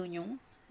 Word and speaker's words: oignon: Un oignon, des oignons, c'est la même chose, hein. Ce OI se prononce ici simplement oignon, comme s'il n'y avait pas de I du tout.